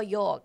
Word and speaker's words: yolk: จริงๆมันคือคำว่าโยกโยกสะกดว่า YOLK